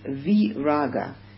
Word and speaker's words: viraga: The equanimity of the mind, which is that same thing that dispassion would denote, in Pali, dispassion is viraga. Now, vi is the non-syllable. There are two non-syllables. One is a, and one is vi. And one is ni. There's three of them. So, and raga is the English rage.